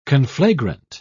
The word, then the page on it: conflagrant: British English